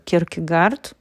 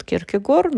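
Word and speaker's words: Kierkegaard: Если мы посмотрим на такого философа, как Киркегард, по-русски еще произносят Киркегор, но я предпочитаю немецкий вариант произношения,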